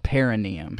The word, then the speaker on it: perineum: Yeah, and even then I got it wrong. It's perineum.